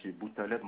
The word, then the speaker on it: Boutaleb: Boutaleb Mohamed. – Alors, comme ça se prononce, Boutaleb? – B-O-U-T-A-L-E-B.